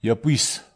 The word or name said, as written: iapwis